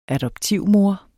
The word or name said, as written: adoptivmor